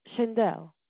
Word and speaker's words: Shindel: Shindel. – Shindel. Oh, it's just like a spell. – S-H-I-N-D-E-L.